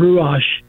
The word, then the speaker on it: Ruach: The Hebrew name for the spirit is Ruach, and I think it was pronounced Ruach.